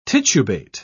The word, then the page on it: titubate: British English